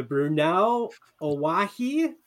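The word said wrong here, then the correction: Bruneau Owyhee: Did I pronounce that correctly? – You're close. It's Bruno Owyhee. – Bruno Owyhee, Sage Grouse Habitat Project Outreach Coordinator in Idaho.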